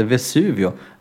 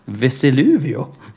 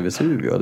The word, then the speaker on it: Vesuvio: märkliga uttalet att han, att han uttalade, uttalade Vesuvio. Vesiluvio. Va? När jag frågade Nej. vilka pizzor de hade. Då sa han att de hade en Vesiluvio. Det tycker jag var märkligt.